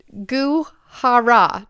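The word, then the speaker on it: Goo Hara: It's G-O-O, like Gube. – Oh. – Gu. And then last name is H-A-R-A. Hara.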